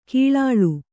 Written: ಕಳ್ಳಾಳ್

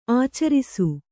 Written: ಆಚರಿಸು

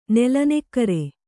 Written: ನೆಲನೆಕ್ಕರೆ

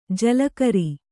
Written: ಜಲಕರಿ

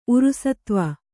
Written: ಉರುಸತ್ವ

